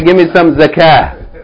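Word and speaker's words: zakah (زكاة): Salah, zakah.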